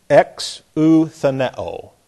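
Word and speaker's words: exoutheneō: This is E, X, O, U, T, H, E, N, E, O, long O on the end. The omega is always long. Exutheneo.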